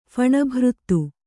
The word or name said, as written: ಫಣಭೃತ್ತು